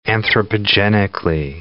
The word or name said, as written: anthropogenically